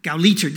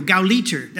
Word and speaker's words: Gauleiter: Anybody speak German? Gauleiter, there we go, Gauleiter, that's how you say it.